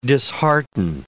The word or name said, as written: dishearten